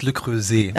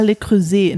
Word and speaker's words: Le Creuset: Ähm, ähm, Spricht man das so aus? Äh, du hattest doch Französisch in der Schule, sag mal bitte. – Es heißt Le Creuset. – Ah, Le Creuset, Entschuldigung bitte.